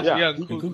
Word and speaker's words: Nkunku: Nkunku was in training.